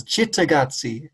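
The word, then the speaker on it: Cittàgazze: Sitakazi? – I put it an Italian soft C with the ch sound because I like how it sounds. Chittagazzi. That's how I say it.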